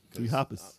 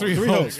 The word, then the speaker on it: Three Hopes: They pronounced it Hoppas. – No, they didn't. They did not. Stop it. Don't listen to this guy. – Such an asshole. – It's Three Hopes.